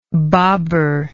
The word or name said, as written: Babur